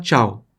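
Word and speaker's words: ciao: Como, por exemplo, para falar tchau em italiano, a gente fala tchau também, né? Como em português, só que se escreve assim, ó. Tchau, estão vendo ali em cima? Tchau, porque CI tem som de ti.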